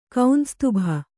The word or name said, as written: ಕೌಂಸ್ತುಭ